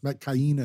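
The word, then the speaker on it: Metkayina: There's so much depth and so much, but there were parts in this movie where, especially once they got to Metcaina, that's how it is pronounced. Metcaina.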